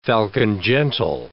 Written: falcon-gentle

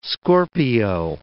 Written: Scorpio

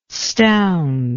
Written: stound

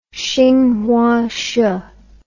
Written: xinhuashe

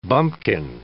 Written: bumpkin

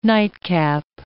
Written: nightcap

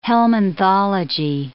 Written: helminthology

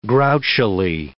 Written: grouchily

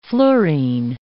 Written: fluorine

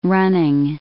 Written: running

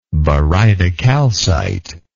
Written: barytocalcite

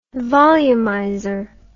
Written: volumizer

